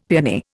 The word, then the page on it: paeony: Indian